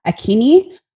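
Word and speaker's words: Akinyi: I am not a social media person, but you can find me on Facebook under the name Tina Akini.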